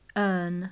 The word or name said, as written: earn